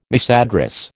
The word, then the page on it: misaddress: American English